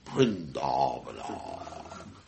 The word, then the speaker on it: Vrindavana: As Prabhupada would pronounce it, Brin, he'd use a V instead of a V. He said, Vrindavana. That's how Prabhupada's pronunciation was.